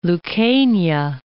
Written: Lucania

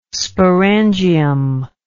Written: sporangium

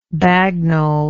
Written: bagnold

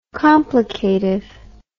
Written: complicative

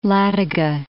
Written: Ladoga, Lake

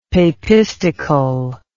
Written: papistical

